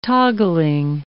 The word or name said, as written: toggling